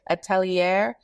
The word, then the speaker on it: atelier: Atelier.